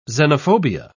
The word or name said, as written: xenophobia